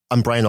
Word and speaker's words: Bryan: I'm Bryan Alston, and my name is spelled B-R-Y-A-N. And my last name is Alston, A-L-S-T as in train, O-N.